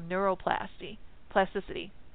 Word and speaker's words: neuroplasticity: neuroplasticity is how it's pronounced.